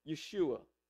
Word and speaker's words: Yeshua: Yes, his name is Jesus, but they would have pronounced it Yeshua.